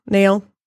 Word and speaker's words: Nail: Is it Nile? Nile? – Nail? – Nail from Dragon Ball Z, as well as tons of others.